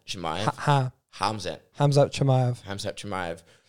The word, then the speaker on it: Khamzat: I believe in Russia you say Hamzat, and other places you'll pronounce the K.